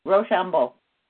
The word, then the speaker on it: Rochambeau: Rochambeau.